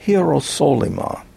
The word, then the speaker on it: Hierosolyma: As it appears here in the text, it looks like Hierosolyma. That's the way one would pronounce it in Greek.